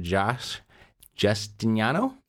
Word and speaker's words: Josh Justiniano: So without further ado, I introduce to you Mr. Josh Justiniano.